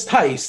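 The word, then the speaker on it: Thybulle: Well, it's Thice. The H is silent.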